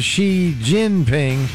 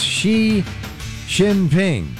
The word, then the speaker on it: Xi Jinping: A lot of media people pronounce it Xi Jinping. It's spelled J-I, but it's Xi Jinping.